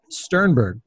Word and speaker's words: Sternberg: We are here with Josh Sternberg.